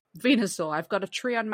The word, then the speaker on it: Venusaur: And this is Venusaur.